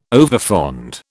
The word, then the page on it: overfond: British English